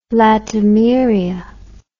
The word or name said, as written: latimeria